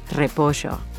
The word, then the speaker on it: repollo: You'll hear that Marcelo pronounces his double L's and Y's as sh. Like in the word repollo, he'll say repollo.